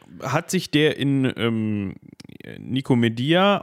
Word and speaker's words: Nikomedia: Ich würde sagen Nikomedia, aber ich bin da auch vorgeprägt durch den Lateinunterricht. – Ach so, ja, aber gut, wenn, wenn das im Lateinunterricht äh, Nikomedia ausgesprochen wurde, dann ähm, bleiben wir dabei.